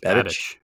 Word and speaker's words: Babich: The Bills also hired or promoted, how do you say his last name? Bobby Babich? – I think it's Babich. Yeah.